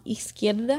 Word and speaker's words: izquierda: Esquerda. – Y derecha. – Derecha. – No, izquierda. – Esquerda.